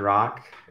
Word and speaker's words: Rak: Hi, my name is Dr. Rock. It's uh, spelled R A K, pronounced R O C K.